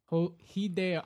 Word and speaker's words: Hideo: Hideo Kojima? – Yeah, is that his name? – Well, yeah, that's how you would pronounce it in Japanese. Hideo.